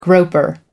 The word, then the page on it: groper: American English